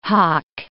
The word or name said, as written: hock